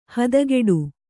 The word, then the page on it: ಹತ್ತಗಡೆ: Kannada